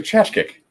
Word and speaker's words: Tkaczyk: I say it to Tchatchkick, but it is spelled T-K-A-C-Z-Y-K.